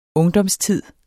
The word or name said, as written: ungdomstid